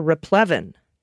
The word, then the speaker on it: replevin: First up, Dana, pronunciation correction from Cuddly Turtle. The word is replevin, not replevin, but rather replevin.